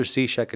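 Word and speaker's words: Ciszek: Father Walter Cichek, it's spelled C-I-S-Z-E-K. C-I-S-Z-E-K.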